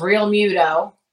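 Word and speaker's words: Realmuto: Well, the big favorites seem to be this Real Muto guy.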